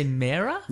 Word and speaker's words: Mera: How do you say it? – I say Mera. – Yeah, I say Mera as okay.